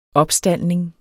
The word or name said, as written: opstaldning